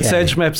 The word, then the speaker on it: SageMap: Well, I think you're one of the first people to pronounce it, and I think one can pronounce it as one wants. – However you want. Okay. – But SageMap sounds right to me.